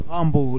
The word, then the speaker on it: Rambeau: o Rambeau, creo que él se pronuncia Rambeau. ¿Cómo se pronuncia? – Rambeau, listo.